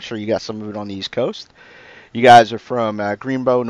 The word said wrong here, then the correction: Greensboro: North Carolina, right? – Greensboro, yeah. – Greensboro, excuse me. Said that wrong.